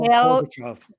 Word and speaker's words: Mikhail Gorbachev: Mikhail, Mikhail Gorbachev.